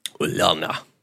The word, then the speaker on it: Ulana: Yeah, I think they pronounce that with a U sound. – Ulana.